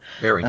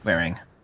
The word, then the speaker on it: Waring: It's pronounced spelled W-A-R-E-I-N-G. Alan Waring. – Waring. – Waring? Thank you. – Waring. – Yep. Waring.